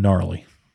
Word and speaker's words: gnarly: And gnarly. – Spell with a G. – Yep. The G is silent.